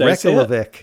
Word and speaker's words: Reykjavik: Yeah, so yeah, they are an Iceland, according to Wikipedia, an Icelandic post-rock band from, well, how do you say it? Reykjavik. Reykjavik. – Yeah, perfect. Reykjavik.